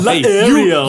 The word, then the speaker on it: Laeriel: Laeriel. – Hey, you put an H in there, and I know you want it to be silent. – The H is silent.